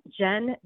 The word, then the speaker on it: Jenn: I am on Instagram at I am Jen, J-E-N-N, Hernandez.